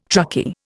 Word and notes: truckie: Indian

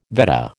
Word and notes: Vedda: American English

